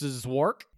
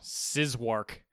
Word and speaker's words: Szwarc: Janos Zwark. – I'm sure you nailed it. – Something like S-Z-W-A-R-C is the last name. – Wow. – Sizwark. is what I'm going with.